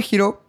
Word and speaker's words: Hiro: Para probar Giro, puedes ir a registrarte al waitlist y ser de las primeras personas en tener acceso. Así que puedes ir a giro.cool. Giro se escribe H-I-R-O.